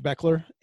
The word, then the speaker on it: Bechler: On Twitter, they can find me at Coach Beckler. And the last name is spelled B-E-C-H-L-E-R. It's like the old legendary Michigan football coach, Bo Schembechler. So it's spelled like Bo Schembechler.